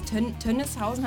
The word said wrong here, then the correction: Tönnishäuschen: habe ich das richtig ausgesprochen? Tönnishäuschen. – Ach, Entschuldigung, Tönnishäuschen.